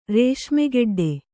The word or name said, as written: ರೇಷ್ಮೆಗೆಡ್ಡೆ